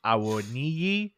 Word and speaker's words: Awoniyi: Let's close it out with the name you were able to pronounce correctly, and that's Awoniyi, 1-0 to Nottingham.